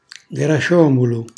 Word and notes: grasciomulu: Calabrese

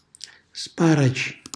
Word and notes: sparaci: Calabrese

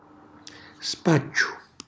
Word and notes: spacciu: Calabrese

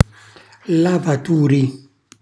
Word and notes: lavaturi: Calabrese

